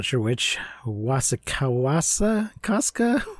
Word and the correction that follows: Wasikowska: Wasikowska as Ava.